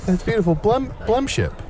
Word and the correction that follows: blendship: Am I saying it right? Blemship. – Blendship. Blendship. It's the perfect blendship.